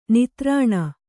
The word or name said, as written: ನಿತ್ತರಣೆ